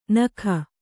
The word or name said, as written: ನೆಕ್ಕು